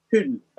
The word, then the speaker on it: Hull: So my city is Hull. It's like, so I guess it'd be pronounced Hoon, in a sense.